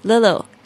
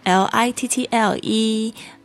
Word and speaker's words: little: 不是，小事情 the little things in life， little l i t t l e。然后记得在讲这个字的时候，美式发音这个 TT 会发成有点类似 T 跟 D 之间的浊音，